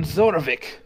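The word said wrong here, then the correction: Zarovich: I think it's pronounced Zorovich. – Oh, Zorovich. My apologies. – You hear a voice? – Didn't know that it was Russian. – It's von Zorovich!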